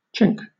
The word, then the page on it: chink: British English